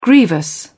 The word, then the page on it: grievous: British English